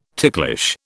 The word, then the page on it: ticklish: British English